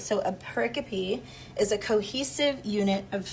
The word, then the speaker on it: pericope: Okay, I said that wrong earlier. A pericope.